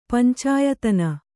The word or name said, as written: ಪಂಚಾಯತನ